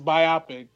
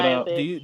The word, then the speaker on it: biopic: And it's actually pronounced biopic, guys. If you could just fix that biopic.